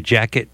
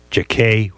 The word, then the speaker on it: Jacquet: along with Illinois Jacket, Jacquet, whatever way you pronounce it, heck of a tenor player, and of course Count and his men and his terrific rhythm section.